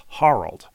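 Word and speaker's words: Harald: It's H-A-R-A-L-D, and it's pronounced Harold. Har as in car.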